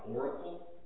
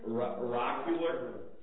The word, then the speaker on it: oracular: I can't even pronounce this word, so I'll spell it. O-R-A-C-U-L-A-R. Oracle? – Oracular? – Oracular.